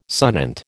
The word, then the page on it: sonant: American English